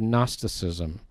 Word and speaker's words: Gnosticism: And it's the heresy of Gnosticism with a silent G, G-N-O, Gnosticism. M-O-U-S-E, Gnosticism, which interestingly did not deny the deity of Jesus.